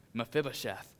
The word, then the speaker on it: Mephibosheth: Jonathan had a crippled son named, I actually have to read it because I always say it wrong, Mephibosheth.